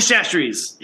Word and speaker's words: Chachere: Tony Chachere's. Yeah. – Tony Chachere. – That's Tony the best. – Chachere. Yeah, like that. You just got to say it like that.